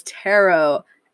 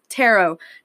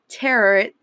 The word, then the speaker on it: tarot: 11 is tarot. And yes, it's pronounced tarot, not tarot, like you pronounce carrot. It's pronounced tarot, like how you say sparrow.